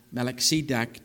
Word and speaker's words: Melchizedek: Verse 18, "And Melchizedek," or Melchizedek, to pronounce it properly, "king of Salem, brought forth bread and wine, and he was the priest of the Most High God.